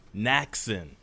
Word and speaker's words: Nachsin: Like Jackson with an N. – Jackson. Nachsin.